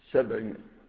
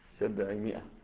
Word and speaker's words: سبعمائة: سبعمائة. نعم. والألف لا تنطق، تكتب ولا تنطق، سبعمائة.